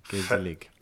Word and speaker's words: gezellig: Or maybe a Dutch word. I shouldn't be doing this. I'm not great with languages. Gezellig. – Gezellig. I think it's, I'm going to try. Gezellig. Gezellig? It has two gezellig. You have to do it twice.